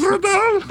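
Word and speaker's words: Øvredal: An O with a line through it. V-R-E-D-A-L. – Overdahl. – How do you pronounce that? – Overdahl. – That mess. – Andre Overdahl.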